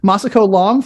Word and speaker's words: Masako Long: Masako Long from Janusi, right below me. Masako, did I get the name right? Oh my gosh. – Yes, you did.